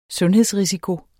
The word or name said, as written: sundhedsrisiko